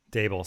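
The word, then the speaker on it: Daboll: Interviews have been had with Robert Saleh, Marvin Lewis, Joe Brady, Brian Dabble, Eric Bieniemy. Dable, sorry, I did it again.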